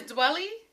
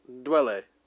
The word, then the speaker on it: Dwele: Is that how you say his name? Is Dwelly? – Dwelle. – Dwele? I never knew until today.